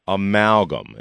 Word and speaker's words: amalgam: There has been a controversy with something that is called a dental amalgam. Am I pronouncing that correctly? – That's correct.